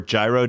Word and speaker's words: gyro: Hey, I was just informed that September 1st is National Hero Day, or Gyro Day for those who just pronounce the food phonetically or the way it's spelled.